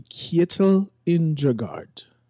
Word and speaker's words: Kjetil Indregard: So that would be Kjetil Indregard.